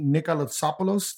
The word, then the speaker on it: Nikolatsopoulos: Our guest this week is Tony Nikolatsopoulos.